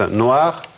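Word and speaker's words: noire: Черная тоже произносится noir, но на письме добавляется нечитаемая буква «э».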